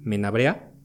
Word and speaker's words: Menabrea: Y este ingeniero italiano, llamado Luigi Menabrea, eh, como tal se puede pronunciar así, nada más que con acento italiano, este, él escribe un artículo, pero en idioma francés.